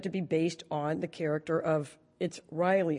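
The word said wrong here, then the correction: Rielle: Hunter, I think it's pronounced. – It's pronounced Riel. – Riel, okay. Riel, I was told Riley by somebody else, but Riel Hunter.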